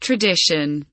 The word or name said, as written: tradition